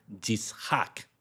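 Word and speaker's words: Yitzhak (יצחק): Esto se pronuncia, se dice en español Isaac, pero la pronunciación en la lengua original es Yitzhak.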